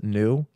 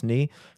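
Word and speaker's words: nu: And they had to skip the letter N before O because we pronounce it new, even though in Greek it's pronounced ni,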